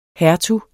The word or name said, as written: hertug